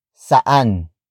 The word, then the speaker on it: saan: Kwarto is spelled as K-U-W-A-R-T-O. It means bedroom. Another example. Saan sila kumain? Saan sila kumain? Saan sila kumain? It means, where did they eat? Word for word, it's saan sila kumain? Saan sila kumain? Saan sila kumain? The sentence starts with saan, which is spelled as S-A-A-N.